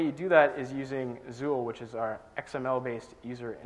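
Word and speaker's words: XUL: But anyway, in the end, you can pronounce it Zool, which I think is really the point of the whole exercise.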